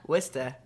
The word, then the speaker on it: Worcester: Worcester, from Worcester. – Worcester.